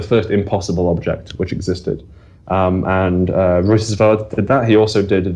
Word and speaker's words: Reutersvärd: Oscar Reutersvärd, I believe, is the pronunciation.